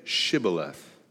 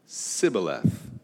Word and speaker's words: Shibboleth: When he said, "No," they said to him, "Then say, Shibboleth." And he said, "Sibboleth," and he could not pronounce it right.